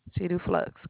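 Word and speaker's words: Seduflux: Is that how it's pronounced? – Cedu Flux, correct.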